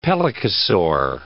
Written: pelycosaur

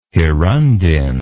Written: hirundine

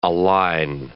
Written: aline

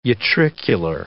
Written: utricular

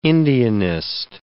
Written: Indianist

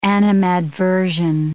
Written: animadversion

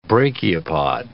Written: brachiopod

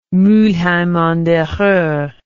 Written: Mulheim an der Ruhr

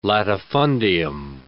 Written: latifundium

